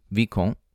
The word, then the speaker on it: Vicomte: Vicomte would be the French pronunciation, or Viscount, in English.